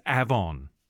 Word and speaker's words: Avon: Yes, that is how you pronounce it. It's not like the Avon Lady out 2 hours inland from Perth. It's Avon. Look it up.